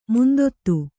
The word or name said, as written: ಮಂದತೆ